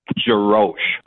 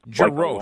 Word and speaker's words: Jarosch: It's uh, it's spelled weird, but it's pronounced Jarosch. – Jarosch. Like long O.